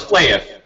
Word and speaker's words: Flahiff: it's Flahif, is that how you say it? – Pretty close, Flahif.